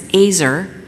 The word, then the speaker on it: Ezer: It's Azer, pronounced like razor, but it's spelled E-Z-E-R, and also Lost Women of the Bible.